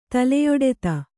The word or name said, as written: ತಲೆಯೊಡೆತ